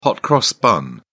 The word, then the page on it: hot cross bun: British English